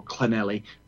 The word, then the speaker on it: Llanelli: And I think, obviously, largely, that's being driven by the fact the government don't want it's not actually pronounced Llanelli, it's more Llanelli. It's double L, but that's how the Welsh pronounce it.